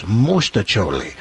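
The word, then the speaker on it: mostaccioli: I have to correct you because the Italian pronunciation is mosticholi.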